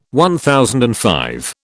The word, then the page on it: MV: British English